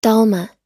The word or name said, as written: dolma